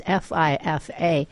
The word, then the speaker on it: FIFA: And I wanted to pronounce it correctly. It's FIFA. And so you can Google anything like that. – So how do you say the name of this organization? FIFA is essentially how you say it if you're curious what it stands for.